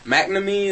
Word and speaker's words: McNamee: Watching them tear apart McNamee,